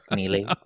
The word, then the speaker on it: Neely: And also, like, great drag name is Neely O'Hara, but like spelled like knee, K-N-E-E. – Oh, Neely O'Hara. – Neely.